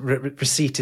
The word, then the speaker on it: receipt: Receipt is one of those words in English where a silent letter has been added by well-meaning classicists desperate to show off its origin in Latin, recipere, right?